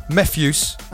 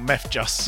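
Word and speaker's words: Mefjus: Mephius, and that's the right pronunciation. – Mephius, not Mephjus. – Not Mephjus.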